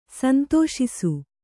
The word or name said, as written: ಸಂತೋಷಿಸು